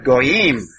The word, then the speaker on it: Goyim: So, we have Gentile empires, Gentile rulership, and if you look at the specific words, this is the Hebrew word, and all of you can pronounce that, right? Very good. Goyim. Yeah, you got it.